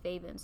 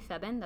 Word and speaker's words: Feven: But I was like, at the end of the day, like, the cultural way to say my name is Feven, so say it that way.